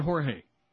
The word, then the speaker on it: Jorge: And the Mad Dog and Jorge in midday. Rhymes with gay.